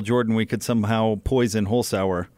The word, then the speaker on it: Holzhauer: The contest could end as early as Friday if Jennings or Holzhauer, is that how you say his name? – Yeah.